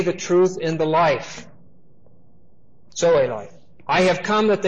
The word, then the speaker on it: Zoe: Now the word for life here is the word Zoe. Z-O long E. Zoe is the word that's always used for eternal life in the New Testament.